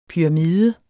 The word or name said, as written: PYRAMIDERNES